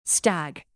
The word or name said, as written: stag